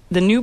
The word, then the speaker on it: GNU: And the new birthday, so there's another one, and it actually is new, GNU. – pronounced new.